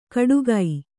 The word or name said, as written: ಕಡುಗೈ